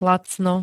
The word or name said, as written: lacno